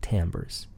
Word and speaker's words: timbre: We'll also discuss what timbre is, often mispronounced because it's spelled T-I-M-B-R-E. But timbre is the quality of the pitch, the way it sounds.